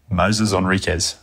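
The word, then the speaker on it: Moisés Henriques: So is it Moses? Is it Moises? Is it Enriquez? Is it Enriquez? Tell me right now, what do you call him? Full name. – Moses Enriquez.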